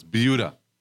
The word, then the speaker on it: Buda: No, it's not Buda, it's Buda. – Buda. – They get upset if you call it Buddha, but it's spelled B-U-D-A. – Right. – And the reason why it's supposed to be spelled V as in Victor, I-U, D-A, Buda. Viuda in Spanish.